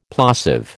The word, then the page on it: plosive: American English